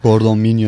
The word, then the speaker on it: Gordon Milne: Gordon Milne, doğru telaffuz ediyorum değil mi? – Evet, Gordon Milne yönetiminde.